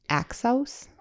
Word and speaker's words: Axios: How do you think you pronounce this website? AXIOS. Axios? – AXIOS? Axios is what I was saying.